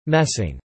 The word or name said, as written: messing